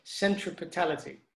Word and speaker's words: centripetality: And the key word there is one that I'm struggling to pronounce, centripetality.